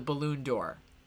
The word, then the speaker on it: Ballon d'Or: I'm sorry, the balloon door.